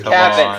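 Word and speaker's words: Cavan: Would you choose pronounce it like Cabin? – That's how it's pronounced. – It just sounds like you're mispronouncing Kevin. – My gosh. Who pronounces Biggio and T.J. Walker? – It's how they announce. I promise you, that's how it's announced. It's not. It's Cabin Biggio.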